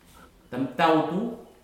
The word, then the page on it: tamtautu: futuna aniwa